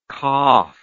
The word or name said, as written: qaf